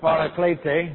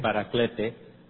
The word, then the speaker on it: paraclete: Pronounced as the Spanish would do it, paraclete. – Paraclete, en español. – But it is paraclete in the Greek.